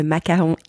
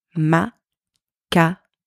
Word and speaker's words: macaron: Today, we are going to focus on the pronunciation of a delicious word, the very iconic French macaron. You probably all know what a macaron is, but if you don't, feel free to Google it and it will definitely make you want to eat one of these.